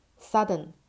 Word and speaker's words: Southern: Southern 是南方的，它不是 Southern。 Southern 是错误的发音。